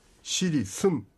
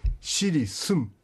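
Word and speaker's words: シリスㇺ: はい、この発音なんですけども一番最後に発音したシリスムというのがありますが M の発音ですので口を閉じてですね、シリスンというふうに、スンというと N の発音になります。これは M の発音ですね。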